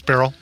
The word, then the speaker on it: Beryl: Fast food chain Whataburger app had gone viral in the wake of the Hurricane Beryl. Is that how we're pronouncing that? – Yeah. – Okay. – Yeah, it's Beryl. Yep.